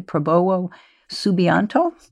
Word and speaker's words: Prabowo Subianto: I'm going to say his name incorrectly, Prabowo Subianto? – That's correct. Very good.